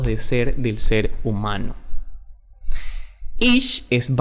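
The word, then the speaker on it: ish (איש): Varón se dice ish. I-S-H.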